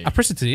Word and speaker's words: apricity: I'm not sure if I'm pronouncing that how he pronounces his shoes, but yeah, look. Apricity. Oh, sorry. – Just because he's got a lisp. – Apricity. – yeah, he pronounces it apricity.